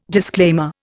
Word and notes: disclaimer: Indian

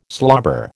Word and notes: slobber: American English

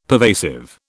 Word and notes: pervasive: British English

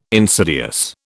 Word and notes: insidious: British English

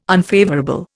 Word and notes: unfavourable: Indian

